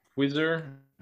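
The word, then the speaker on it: Qzzr: Um, so I use one uh, either Typeform, Typeform, or the other one I use it's bit, uh, is is Quizzer, uh, but it's not spelt like quiz. Uh, it's it's spelled Q Z Z R, Q Z Z R dot com.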